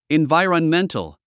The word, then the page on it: environmental: invài ə rənméntl; インバイロンメントゥ